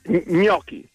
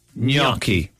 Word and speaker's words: gnocchi: Gnocchi. – Gnocchi. Okay. – The Italians have very few pronunciation exceptions, and one is G N together. You read it as gnu. – Gnu. – So it's gnocchi.